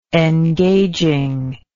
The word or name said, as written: engaging